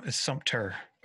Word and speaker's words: Sumter: That's the first time I've ever heard Sumter, but it's, I often hear Sumter where they spell it with a P, Sumter, but it's Sumter. Anyway, who cares? – No, dude, it's your state, your town.